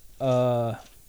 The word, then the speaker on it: Iosivas: You had, is it Iosivas? Iosivas? The Princeton guy. – I think it's pronounced Yosivash.